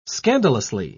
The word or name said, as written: scandalously